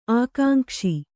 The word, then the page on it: ಆಕಾಂಕ್ಷೆ: ākāŋkṣe